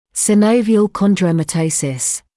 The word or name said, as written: synovial chondromatosis